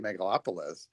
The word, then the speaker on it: Megalopolis: Yeah, Megalopolis. – That have just been ripped by critics. Like, I haven't seen either one, but it kind of makes me interested, especially Megalopolis, because people are saying it's just a disaster.